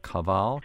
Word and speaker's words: kaval: And to complete people's education here, that is spelled T-S-I-M-B-L. Cymbal. And then there's, what's this other instrument, K-A-V-A-L, Kaval?